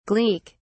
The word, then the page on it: gleek: British English